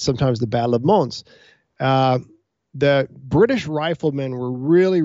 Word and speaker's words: Mons: And the S is pronounced, according to my French friend, Mons.